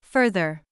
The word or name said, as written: further